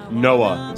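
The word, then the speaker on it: NOAA: Noah is how I pronounce that.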